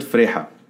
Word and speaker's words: Freiha: I said Freiha. – That's it. You said it with the Arabic pronunciation. – So both works, I guess. – Yeah, depending on who your audience is. For the Arabs, it's Freiha.